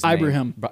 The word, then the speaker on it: Ibrahim: The rushing yards that they allowed against Minnesota last night, Muhammad Ibrahim, I believe is how you pronounce his Ibrahim. – Name. Ibrahim.